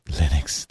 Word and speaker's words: Linux: For a long time when you installed Linux, it would come with one sound file that would say, my name is Linus Torvalds and I pronounce Linux.